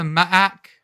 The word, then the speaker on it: MAAC: Is that how you pronounce that conference? The MAAC? – Yeah, it is now.